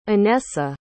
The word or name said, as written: inessa